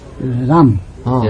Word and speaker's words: Rama: It's Ramo, Ramo. That is the true pronunciation. But if one says Ram, that doesn't mean that will be nothing.